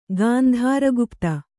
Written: ಗಾಂಧಾರಗುಪ್ತ

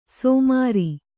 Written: ಸಮರು

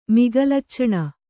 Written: ಮಿಗಲಚ್ಚಣ

